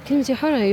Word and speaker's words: Kamti Hora: What's the correct pronunciation? – Kamti Hora, you pronounced it.